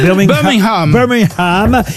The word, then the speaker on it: Birmingham: On ne doit pas prononcer le G, hein, il paraît. – Birmingham est effectivement...